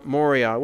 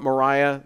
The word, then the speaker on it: Moriah: So Abraham journeyed with Isaac to Mount Moriah, which is actually the correct pronunciation. If you want Moriah, that's fine.